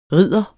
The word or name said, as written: ridder